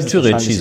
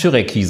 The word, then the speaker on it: Türeci: Ja, sprich, äh, dann spricht man das so aus, ja? Ich würde da eh Türeci sagen. Ich äh, würde jedenfalls nicht Türeki sagen. Und ja, Türeki klingt auch ein bisschen komisch.